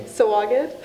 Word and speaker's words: Sawaged: Sawaged.